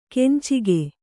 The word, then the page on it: ಕೆಂಚಿಗೆ: kencige